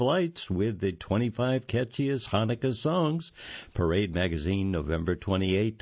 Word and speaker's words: Hanukkah: Hanukkah, frequently spelled with a silent C as the first letter, is an eight-day commemoration of the miracle of light that ended December 6th this year.